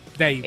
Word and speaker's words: Dave: David Grohl. – Dave. – Dave. Ah, entonces lo dije bien. – Se dice Dave.